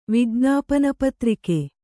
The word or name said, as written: ವಿಜ್ಞಾಪನಪತ್ರಿಕೆ